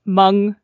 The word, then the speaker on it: Hmong: So just for reference, Hmong, and it's spelled H-M-O-N-G, and I had to look up how to pronounce it.